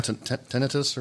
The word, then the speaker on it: tinnitus: It's called tinnitus, or is that how you say it? – Yeah, That's how you say it.